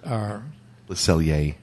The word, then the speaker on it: Le Cellier: I mean, the garden over there is spectacular, going over to La Cellière, and it always is. But the R is silent. Le Cellier. – Le Cellier.